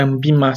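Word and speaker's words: ANBIMA: Então, CGA é a certificação dos gestores da Ambina. É Ambima, sempre falo Ambina.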